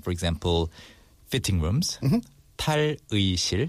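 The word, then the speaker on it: 탈의실: Also, yeah, tal-ui is supposed to be pronounced as 의 in the original pronunciation. – But people tend to say 이. – Tal-ui-shil. – They say 다리실.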